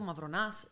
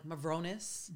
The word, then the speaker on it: Mavronas: It's very short. It's simple, Mavronas. It's not difficult. Yeah. The American way, it's just like Mavronas.